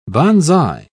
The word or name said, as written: banzai